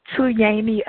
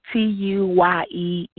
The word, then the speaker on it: Tuyeni: Tuyeni Akanke. Tuyeni. And then Akanke. – All right, spell that one more time. T-Y? – T-U-Y-E-N-I.